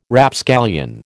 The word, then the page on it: Rapscallion: American English